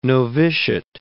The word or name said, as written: novitiate